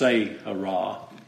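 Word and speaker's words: Seirath: And I believe that's pronounced Seirah.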